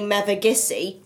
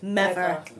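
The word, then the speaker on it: Mevagissey: And a local would never say mevagissi. We'd always say mever.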